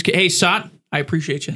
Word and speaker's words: Sat: And I used to play the heck out of that game with my buddy Sot. Shout out Sot. – His name is Sot. How do you spell it? – S-A-T. Satyam Vakil. – Well, his name's Sat. So. – No, it's not. – S-A-T.